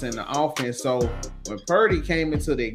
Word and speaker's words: Purdy: Brody or Birdie? How they pronounce his name? – Purdy. The quarterback.